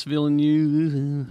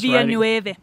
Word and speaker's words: Villeneuve: Denis Villeneuve, who is Villeneuve.